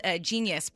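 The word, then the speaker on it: Jeen-yuhs: It's called Genius, but it's spelled G-E-E-N space Y-U-H-S.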